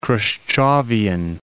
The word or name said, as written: Khrushchevian